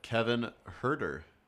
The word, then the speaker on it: Huerter: Yeah, here on the ESPN draft special at 17, Kevin Huerter. Am I saying that right? – Yep.